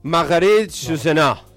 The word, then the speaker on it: Marguerite Yourcenar: Dice Marguerite Yourcenar, ¿cómo se dice el apellido de la escritora francesa? – What? No tengo ni idea. – Marguerite Yourcenar. ¿Cómo lo pronunció en francés? Vos que sabés de esto.